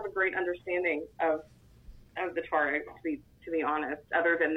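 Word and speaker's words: Tuareg: In addition to these small Islamic groups, insurgencies, jihadists, you've also got the Tuaregs. Who are they? Or did I say that correctly? Tuaregs? – You did. – That's actually where I was going to go to. – Yeah, just like the Volkswagen.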